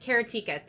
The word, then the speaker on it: Karateka: Karateka. – That? Karateka, thank you.